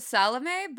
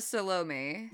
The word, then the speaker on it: Salome: I've always heard it Salome, but she says Salome.